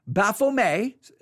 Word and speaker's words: Baphomet: Baphomet, uh, the T uh, is usually not pronounced, it's a French word, um, Baphomet, but and uh, sometimes in America you'll hear it pronounced Baphomet, but it's Baphomet. And Baphomet,